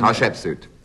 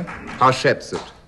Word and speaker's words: Hatshepsut: I know your pronunciation's all wrong. – Well, you give us your pronunciation. – Hatshepsut. – I'm sorry? – Hatshepsut. – I was trying to help you. – Yes, Hatshepsut. You always say Hatshepsut.